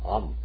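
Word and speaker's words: Am (עם): Am.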